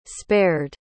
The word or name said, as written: spared